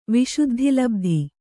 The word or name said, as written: ವಿಶುದ್ಧಿಲಬ್ಧಿ